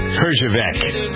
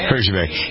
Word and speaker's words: Herjavec: And later in the hour, we'll have from Shark Tank, Robert Herjavec. That's how you pronounce his name. Herjavec.